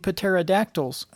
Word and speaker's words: pterodactyl: Oh, we're pronouncing the P in pterodactyl now? – Yeah, and the K's I mean, back before the 1500s, they pronounced the P, just like knife.